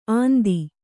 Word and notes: ಅಂದಿ: andi

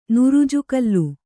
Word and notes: ನುರುಜುಗಲ್ಲು: nugujugallu

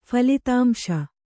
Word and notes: ಫಲಿತಾಂಶ: phalitāmśa

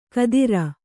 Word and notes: ಘಾತ್ರ: ghātra

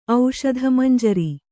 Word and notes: ಔಷಧಮಂಜರಿ: auṣadhamañjari

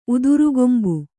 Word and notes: ಉದುರುಗೊಂಬು: Kannada

